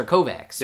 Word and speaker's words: Kovacs: His name is Walter Kovacs. – Yep. – I believe the last one's literally just my face. – If we're going by the Alter Carbon pronunciation, it's Kovac.